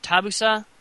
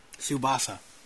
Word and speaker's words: Tsubasa: How did you say that? Tabusa? – Tsubasa. – Tsubasa. Wow. – Yep. – That's silent T, I guess.